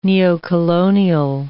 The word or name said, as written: neocolonial